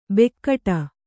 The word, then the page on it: ಬೆಕ್ಕಟ: bekkaṭa